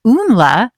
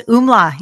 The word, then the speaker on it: Umla: I hope that I said that right. And I'm so glad to have you on. This is so exciting. Hello, Dr. Natterson. – Hello. You can call me Cara. And it's really nice to meet you, Lisa. And thank you for having me. Yes, Umla. You said it right.